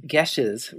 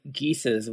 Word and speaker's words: Geases: I believe we decided that we would pronounce it by the actually correct pronunciation, Geshes, rather than Geeses, which gives it kind of a nice sort of animal going to the lake kind of sound. – Yeah, it sounds like make way for ducklings.